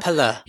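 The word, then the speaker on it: Pelle: How do you say his name? Pele. – Pele. Pele? Pella. Pella.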